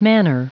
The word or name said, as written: manner